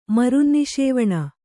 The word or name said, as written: ಮರುನ್ನಿಷೇವಣ